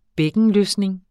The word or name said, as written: bækkenløsning